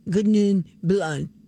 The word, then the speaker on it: Sauvignon Blanc: Uh, if you do want to pronounce it the French way, the genuine French way, you'd say Sauvignon. Sauvignon Blanc.